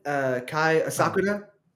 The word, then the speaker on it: Kai Asakura: Kai Asakura, he's dope. He's good. – Good pronunciation, dang. – Yeah, I think my fiance, she knows. She makes sure I say all the Japanese things right.